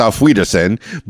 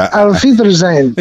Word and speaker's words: Auf Wiedersehen: I think I butchered it. I know it's Auf Wiedersehen, but Auf Wiedersehen.